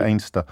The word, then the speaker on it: Anstruther: It is just along the road from Anstruther, as I pronounce it. I think locals pronounce it Ainster.